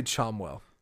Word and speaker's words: Chommell: I would say Chomwell. – Chomel? – C-H-O-M-M-E-L-L. Again, that's coming from a book. It was never meant to be pronounced.